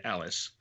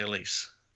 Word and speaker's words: Allyce: And I remembered I read an interview with her, and she had come up with a little rhyme, because people looked at her name and they see Allyce, and people try and say Alice. And so she had come up with this little rhyme to remind people of how to say it. And it she might think you callous if you call her Alice. She might yell police, so please say Elise.